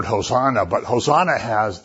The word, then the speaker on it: Hosanna: And if you say Hosanna, it means come and save us now. You know, the accent on the last syllable of this word makes it emphatic.